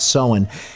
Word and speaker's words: Samhain: When you see it written, that's what it looks like, but it's Samhain.